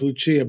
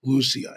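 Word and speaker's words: Lúcia: Most people know her as Lucia, but the correct Portuguese pronunciation is Lucia.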